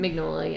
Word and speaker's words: Mignola: Yeah, it's Mike Mignola. Is that how you say his name? – Mignola, yeah.